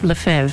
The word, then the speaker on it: Lefebvre: Archbishop Lefebvre, thank you. I've said it before. I had it in my mind, but, you know, when you're on the spot and I'm reading it, I'm going, Lefebvre? Archbishop Lefebvre, thank you, Mr. Cosmic Cowboy Eddie White.